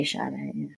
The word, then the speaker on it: Sade: Or Sade? – Sade. – Is that how you say it? – With an S, you say Sade.